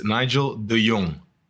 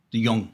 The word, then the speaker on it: De Jong: It's a really hard name to pronounce as well, especially for people abroad. It's Nigel De Jong. – De Jong. – De Jong. De Jong. Yeah. – Okay. So, you put the emphasis on the J. – The J. – De Jong. It's like, Young. – Well, Young. Well, no, you've the turned Young. The J into a Y immediately.